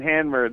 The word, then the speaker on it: Hamner: It's Hamner. Is that how you say it? – Yeah, in Hamner. That's right.